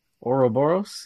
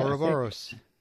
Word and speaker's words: Ouroboros: Ouroboros? Is that how we pronounce it? – Ouroboros, yeah. – Ouroboros.